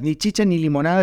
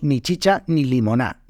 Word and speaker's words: ni chicha ni limonada: decimos ni chicha ni limoná, ni chicha ni limoná.